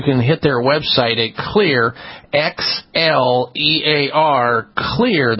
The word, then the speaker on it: Xlear: Clear is spelled with an X, X-L-E-A-R. Clear, X-L-E-A-R, nasal sprays and the Spry Dental Defense products.